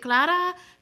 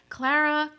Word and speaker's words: Clara: Bro, people, for some reason, they never know how to pronounce my name because they feel like they should put a Spanish accent on it. So they're like, is it Clara? Clara? Clara?